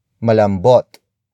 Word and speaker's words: malambot: The first word is malambot. Malambot. Malambot. Malambot is spelled as M-A-L-A-M-B-O-T.